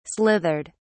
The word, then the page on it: slithered: British English